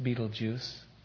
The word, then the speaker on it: Betelgeuse: My favorite star, I think it's pronounced Betelgeuse.